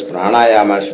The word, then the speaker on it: pranayama: Then pranayama, breath control. You have to pronounce it properly, okay? Pranayama, breath control.